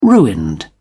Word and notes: ruined: British English

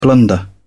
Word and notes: blunder: British English

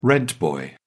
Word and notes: rent boy: British English

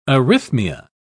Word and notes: arrhythmia: British English

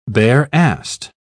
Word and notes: bare-assed: British English

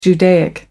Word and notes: judaic: American English

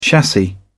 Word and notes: chassis: British English

